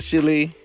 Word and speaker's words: Saleh: Another two is Robert Saleh.